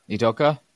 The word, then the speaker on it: Udoka: How do you pronounce his name? I don't want to butcher this one either. Udoka? – Let me look. – Ime Udoka? – Udoka? – Udoka? – Yeah.